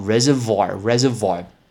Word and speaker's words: reservoir: 它那个是水库，叫 reservoir，reservoir，R E S E R V O I R。我们特别要提醒大家是 O I，你只要在英文单词里面看到 O I，很难念哦，不是什么 oi。哎，不是，我不是叫你什么，哎哎哎，no，不是 oi，那个 o i 都会叫哇哇哇，w a 哇的发音，所以水库这个字叫 reservoir，reservoir。